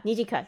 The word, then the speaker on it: Nijika: Nijika. – Oh, Nijika. Why is her name so much more difficult than the others? – What? Well, because it's like Nijika. It's like three syllables.